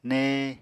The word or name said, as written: née